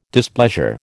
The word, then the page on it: displeasure: American English